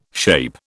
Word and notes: SHAPE: British English

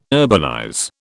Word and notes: urbanize: British English

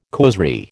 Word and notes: causerie: American English

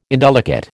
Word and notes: indelicate: American English

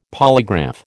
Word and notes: polygraph: American English

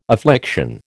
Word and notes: affliction: American English

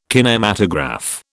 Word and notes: kinematograph: British English

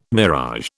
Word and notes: mirage: British English

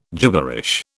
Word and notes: gibberish: British English

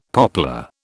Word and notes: poplar: British English

